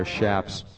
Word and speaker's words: chaps: By the way, we pronounced chaps wrong. They're shaps.